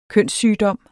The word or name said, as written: kønssygdom